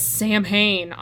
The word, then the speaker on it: Samhain: This is Sam Hain, which, by the way, and I don't think Jacob knows this, but that's not how it's pronounced. – Yeah, no, you told me at one point. – It's Samhain? – Yeah. – It's a Gaelic word.